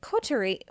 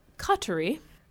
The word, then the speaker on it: coterie: Feeling lost and unsure of herself, she meets and is then turned by a coterie. How do you say that word? – Coterie. – Coterie, okay.